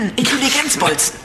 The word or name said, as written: Intelligenzbolzen